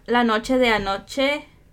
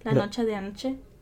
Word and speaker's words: La Noche de Anoche: So, another, like, these, I thought La Noche de Anoche, right? Is that how you say it? La Noche de Anoche? – Yeah.